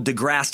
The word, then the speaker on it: DeGrasse: I've been calling him Degrassi as if he's the fucking star of Degrassi Junior High because I don't know how names work. As is pointed out by time sucker JT Henry, it's DeGrasse. No E sound at the end.